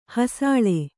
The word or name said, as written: ಹಸಲು